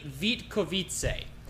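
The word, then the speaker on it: Vítkovice: I looked up the Czech alphabet to make sure I was pronouncing each syllable correctly. So Vitkovice. Regardless, Kenneth, three-year contract.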